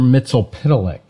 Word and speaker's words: Mxyzptlk: Oh, Mr. Mitzelpitalik.